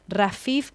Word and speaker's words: Rafeef: Repetimos, nosotros enseñamos la vida, señor, Rafif, que se escribe Rafeef Sida con Z, una artista canadiense palestina.